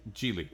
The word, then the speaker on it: Geely: Giggly, remember Giggly? – Geely. – Yeah, I know. I'm saying it wrong.